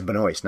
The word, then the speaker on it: Benoist: And incidentally, I heard Chazelle pronounce that in a sit-down interview with Simmons and Teller with some guy. So I guess it is Benoist, not Benoist or anything.